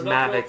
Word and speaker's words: Mavic: It's pronounced Mavic.